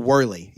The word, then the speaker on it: Worley: No, but also I realized that I think I've been mispronouncing your name for years. – Yeah, we talked about this. I don't have a dog in the fight when it comes to how people pronounce. Like when people say Worley or Worley, I'm like tomato, tomato. – But you say Worley, like Worley ball. – I say Kyle Worley.